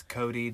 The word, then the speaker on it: Cody: My name is Cody, that's C-O-D-Y.